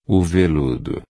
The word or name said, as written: veludo